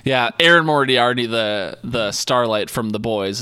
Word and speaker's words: Moriarty: I'll go with number four, Erin Moriarty.